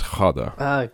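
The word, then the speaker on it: Ghada: I recently asked someone about the pronunciation of this first name, Rob, because I wasn't sure if it was like a hard G, but luckily I have a friend who teaches Persian literature and he knows some Arabic as well, so he helped me out with that. I think it's Khada.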